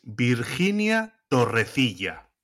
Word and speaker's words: Torrecilla: Yeah, the pronunciation at first is Virginia Torrecilla. – Yeah, now that's the authentic Spanish way of saying it, and something that I just can't do.